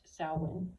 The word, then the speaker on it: Samhain: They celebrated what they called Samhain, spelled Samhain, but it's pronounced Samhain.